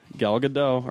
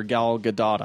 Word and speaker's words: Gal Gadot: Gal Gadot, or Gal Gadot, actually. I guess it's pronounced.